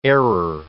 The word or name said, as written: error